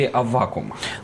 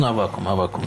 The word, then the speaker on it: Аввакум: Как все-таки правильно произносить? Я понимаю, что две нормы – Аввакум, как сейчас многие говорят, или Аввакум? – Древнерусская традиция – Аввакум.